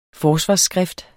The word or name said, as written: forsvarsskrift